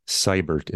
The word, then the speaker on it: Seibert: And the key thing there is my last name, Seibert, is spelled S-E-I-B-E-R-T.